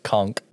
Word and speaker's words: conch: So fun fact, it's actually pronounced conch. They say conch the entire episode, but it's pronounced conch.